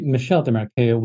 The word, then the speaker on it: Michel Desmarais: His name is actually Michel Desmarais when it's pronounced in French.